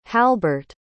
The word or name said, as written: halbart